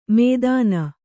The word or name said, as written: ಮೇದನಾ